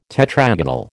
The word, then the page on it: tetragonal: American English